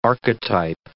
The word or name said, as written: archetype